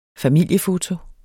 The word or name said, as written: familiefoto